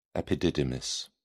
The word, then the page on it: epididymis: British English